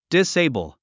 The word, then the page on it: disable: diséibl; ディセイブル